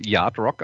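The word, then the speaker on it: yacht rock: Wenn man es amerikanisch korrekt ausspricht, muss man das Yardrock aussprechen, nur mal so am, als, äh, am Rande.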